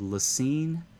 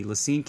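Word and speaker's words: La Cygne: It's in a little town called Lacine, Kansas. I'm going to spell it in case you want to look it up. L-A, capital C-Y-G-N-E. Lacine, Kansas is literally within a few miles of the geographic center of every school campus in America.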